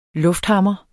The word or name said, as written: lufthammer